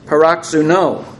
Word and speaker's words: paroxuno: The Greek word is paroxuno, P-A-R-O-X-U-N-O, with the accent on the last syllable.